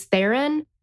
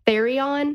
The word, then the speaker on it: Theron: And I mentioned because, well, Tyrella's husband did not know how to pronounce the name Charlize Theron and called her Charlize Therion.